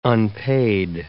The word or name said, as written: unpaid